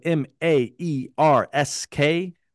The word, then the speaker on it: Maersk: Giants like Maersk, if you don't know Maersk, it's spelled M A E R S K.